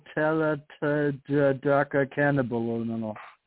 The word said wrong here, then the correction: tetrahydrocannabinol: Was I even close? – Tetrahydrocannabinol. – Tetrahydrocannabinol.